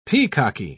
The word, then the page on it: peacocky: British English